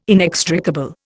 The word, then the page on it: inextricable: Indian